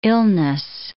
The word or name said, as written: illness